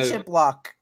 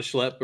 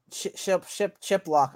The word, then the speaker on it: Shiplock: It's Sean Shiplock. – Oh, Sean Schlepp or whatever. Yeah. – Shiplock.